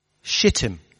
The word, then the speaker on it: Shittim: It's called Shittim. And that's the real pronunciation of it. It's the real pronunciation in the Hebrew.